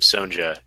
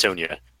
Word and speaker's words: Sonja: Mango, I want to make fun of you because you pronounced her name Sonja, and it's Sonja.